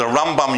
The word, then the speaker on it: Rambam: Now, we don't get confused between the Rambam and the Ramban, because the Rambam, you put the emphasis on the first syllable, and when we say Ramban, we put it, as apart from changing M to N at the end, we put it on the second syllable.